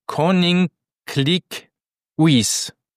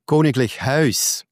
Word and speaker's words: Koninklijk Huis: Jetzt musst du mir sonst korrigieren, und zwar auf Koning Klik Uis. Wie spricht man es auf Niederländisch aus? – Muss lesen, damit ich weiß, was du da hast. Ah, Koninklijk Huis.